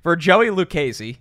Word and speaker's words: Lucchese: I know everybody loves to say it the wrong way, and Joe texted me right away and was like, this is how you pronounce it. Don't say it. Don't say it like everyone thinks. For Joey Lucchese. Did I nail it, Joe? – You got it.